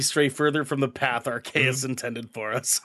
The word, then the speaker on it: Arceus: Is it Arceus, Arceus, Arceus, it's Arceus. – Arceus. – Arceus. – It's pronounced Arceus.